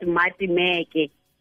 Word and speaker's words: Maatemeke: And then the Instagram is at Maatemeke, the way you pronounce my name.